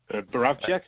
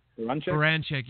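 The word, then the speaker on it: Baranchyk: And then I'm going to butcher this name too, Ivan Baranchik. How do you guys pronounce that one? – Baranchik, I think. Baranchik, yeah. Did I get it right? Wow, I actually got it right.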